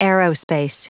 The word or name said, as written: aerospace